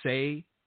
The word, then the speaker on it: Tsay: His first name is Brandon, but his last name, it might be Say, T S A Y. And I want to say that the T is silent.